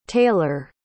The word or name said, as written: tailer